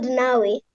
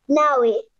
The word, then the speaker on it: Nawe: It's called Nawe. – Say it one more time. – Nawe. – Nawe? – Nawe. – How do you spell it? – N-A-W-E. It's a Kiswahili word.